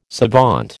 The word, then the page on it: savant: American English